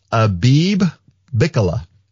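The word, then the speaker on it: Abebe Bikila: I realized that I have been pronouncing this guy's name wrong since I was a teenager. So his actual name is Abib Bikila.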